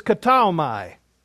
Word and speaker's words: ktaomai: The Greek word is kataomai. Let me spell it. K-T-A, kata, O-M-A-I. Kataomai. K-T, hush. K-T-A-O-M-A-I.